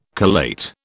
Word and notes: collate: British English

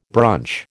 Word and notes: brunch: American English

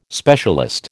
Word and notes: specialist: American English

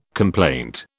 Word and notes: complaint: British English

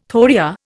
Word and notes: thoria: Indian

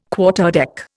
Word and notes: Quarterdeck: Indian

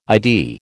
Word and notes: ID: American English

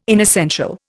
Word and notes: inessential: Indian

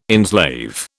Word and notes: enslave: British English

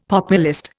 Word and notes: populist: Indian

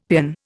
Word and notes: peon: Indian

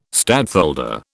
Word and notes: stadtholder: British English

